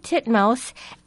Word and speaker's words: titmouse: This is a tufted titmouse, and the syllable T-I-T is firmly affixed to the second syllable, M-O-U-S-E. That's titmouse. That's one word.